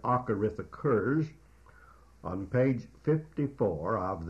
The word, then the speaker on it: acharith: In the Hebrew, there is the word agarith. That is spelled A-C-H-A-R-I-T-H.